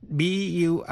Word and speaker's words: bury: Động từ bury, âm u, trong bury đọc như e. To bury nghĩa là chôn. Burial, b-u-r-i-a-l là lễ an táng.